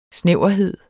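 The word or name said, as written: snæverhed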